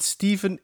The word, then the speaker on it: Stephen: Ja, waarom zegt iedereen Steven? Omdat het Steven is. Maar het is toch Stephen geschreven? Maar dat wordt uitgesproken als zijnde Steven.